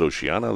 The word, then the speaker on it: Oceania: And even though it's spelled Oceania, they pronounce it Oceana.